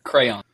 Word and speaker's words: crayon: Somebody in this group, I'm not going to name names, says crown. Crown. Yeah, who? – Crown. – It's a damn crown. – No, there's no way. – It's a you're both wrong. It's a crayon.